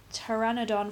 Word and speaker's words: Pteranodon: So over in Islands of Adventure, we have not done the Pteranodon Flyers.